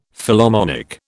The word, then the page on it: Philharmonic: British English